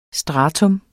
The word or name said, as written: STRATUMS